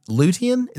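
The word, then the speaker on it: Lutein: And they have a trademark version of Lutean. Is that how you'd pronounce that? – Mm-hmm. Lutean.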